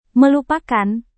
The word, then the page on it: melupakan: Indonesian